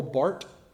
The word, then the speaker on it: Barth: Probably the most influential theologian of the 20th century was a German named Karl Barth, B-A-R-T-H, but pronounced Barth.